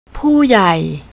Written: puyai

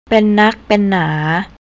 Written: pennakpenna

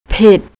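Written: ผิด

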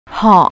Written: hoh

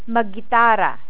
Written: maggitara

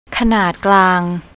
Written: kanatklang

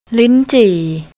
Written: linchi

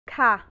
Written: kha

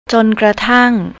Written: jonkratang

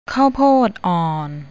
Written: ข้าวโพดอ่อน

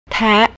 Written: tae

